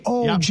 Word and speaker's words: OG: OG, OG. Yep. It's two syllables. It's one letter. OG, like Red G, OG.